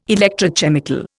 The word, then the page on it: electrochemical: Indian